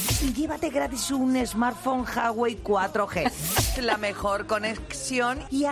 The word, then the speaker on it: Huawei: ¿Pronuncio bien Huawei, por cierto? – Huawei, perfectísimamente es. Bien.